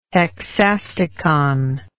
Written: hexastichon

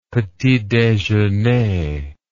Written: petit dejeuner

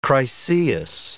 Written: Chryseis

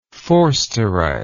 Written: forsterite

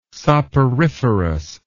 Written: soporiferous

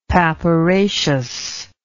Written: papyraceous

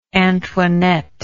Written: antoinette

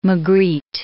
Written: Magritte

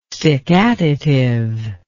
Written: stickatitive

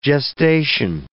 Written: gestation

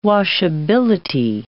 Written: washability

